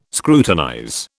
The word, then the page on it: scrutinize: British English